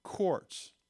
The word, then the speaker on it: quarks: And the smaller particles, they call them quarks, like a cork in a bottle, only spelled with a Q, okay?